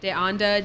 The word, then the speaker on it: De Anda: On Facebook, Ilean, Ilean, De Anda, DE_ANDA.